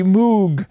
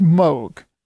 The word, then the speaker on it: Moog: is this the Moog home? And she said, well, the first thing is, it's pronounced Moog.